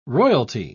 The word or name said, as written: royalty